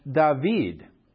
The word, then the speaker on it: David: So remember, in the past, they were one nation and they were under the king of David, or David is the way you say it in Hebrew.